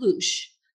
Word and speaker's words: loosh: It's called loosh. And they feed loosh. – How do you spell that? – L-O-O-S-H.